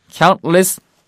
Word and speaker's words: countless: 근데 쌤 왜 발음을 countless 이렇게 안 하고 count 중간에 트 발음이 왜 안 들려요? 그 이유는요. C O U N T 다음에 으 소리가 없죠. 거기에 으가 없어요. 그러니까 우리말에는 으가 있지만 거기다 으를 넣으시면 안 돼요.